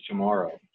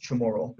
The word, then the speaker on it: Chamorro: So our native language is Chamorro. – Wow. Chamorro. Is that how it's pronounced? Chamorro. Mm-hmm. – Tomorrow. Yep.